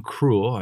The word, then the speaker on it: Krule: this is a song called Flimsier by King Krule. I believe I'm saying that right. K R U L E.